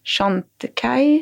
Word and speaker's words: Chantecaille: Jättefin makeup, RMS och Chantecaille, uttalas det så.